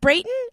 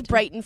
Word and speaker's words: Brayton: Is it Brayton? – Yeah. – I always mispronounced it for a while before really? I even knew you, I think. – It's just like it. It's like spelled. It looks. B-R-A-Y-T-O-N. – I thought it was Brighton for a while.